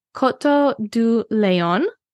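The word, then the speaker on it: Coteaux du Layon: So the first region is Coteau du Lion, and it's spelt like Coteaux de Lion.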